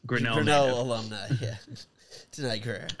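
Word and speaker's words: Danai Gurira: Denai Guerra.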